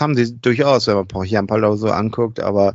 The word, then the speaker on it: Pohjanpalo: Ja, vielleicht hat Pohjanpalo, so wird er ausgesprochen, seine Kontakte spielen lassen.